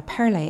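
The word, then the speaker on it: Pearlai: Now, Pearlay, in case you're wondering how to spell that, it's P-E-A-R-L-A-I.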